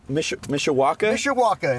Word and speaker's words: Mishawaka: How do you pronounce it? Mishawaka? – Mishawaka in the Mishawaka.